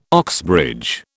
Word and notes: Oxbridge: British English

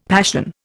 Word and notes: passion: Indian

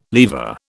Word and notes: lever: British English

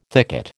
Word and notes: thicket: American English

